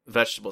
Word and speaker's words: vegetable: It's 4 syllable vegetable, right. Not 3 syllable vegetable.